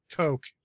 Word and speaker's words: Koch: Saints Row 2, that developer, they got picked up by Koch Media, known as Deep Silver. – Oh, no. – It's pronounced Coke, by the way. – Coke. Sorry. I say Koch because I'm American.